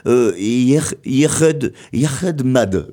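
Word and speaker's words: Yec'hed mad: Alors, ça devrait se prononcer comme... Yerhed Mad, voilà,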